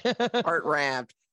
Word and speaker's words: Hartranft: Hartranft.